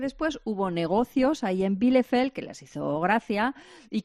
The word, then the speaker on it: Bielefeld: Bielefeld, eso.